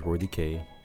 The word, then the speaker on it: Roydke: And yeah, my artist name is Roy DK. It's spelled R-O-Y-D-K-E.